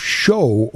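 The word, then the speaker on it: shews: Now, what are shoes? S H E W S. I looked this up. It is an archaic spelling and pronunciation of, of show or shows.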